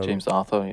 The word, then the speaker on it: James Arthur: Momentan bin ich äh, auf James Arthur, oder wie wird er ausgesprochen? Ähm, James Arthur, ja, würde ich.